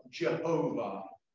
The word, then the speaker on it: Jehovah: The word Lord in the Hebrew language is written YHWH. And in the language of English, we pronounce that as Yahweh or Jehovah.